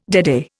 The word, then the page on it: deadeye: Indian